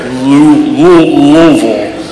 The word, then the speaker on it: Louisville: I woke up in Louisville yesterday. That's how they say it there. Louisville.